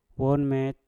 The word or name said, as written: wom met